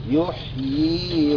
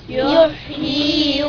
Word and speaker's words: يحيي: يبقى لازم لابد ان احنا ننطق الياء الاولى والياء الثانيه اللي هي الصغيره دي المعكوفه دي. قول وراي. يحيي ويميت. – يحيي ويميت.